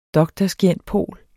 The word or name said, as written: dr.scient.pol.